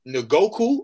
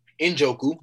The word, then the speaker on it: Njoku: A decent offensive line, David Njoku, or however you say his name, him. – Njoku, my bad.